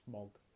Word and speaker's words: Smaug: Smog.